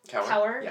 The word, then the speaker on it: Kaur: But I did, thanks to the best-selling antics of, please help me pronounce her name, Rupi Kaur.